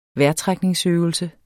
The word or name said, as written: vejrtrækningsøvelse